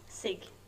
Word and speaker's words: Sig: Sig.